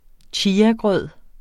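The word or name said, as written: chiagrød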